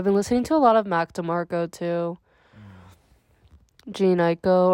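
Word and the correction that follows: Jhené Aiko: Yeah, Janae Aiko.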